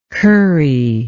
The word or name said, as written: currie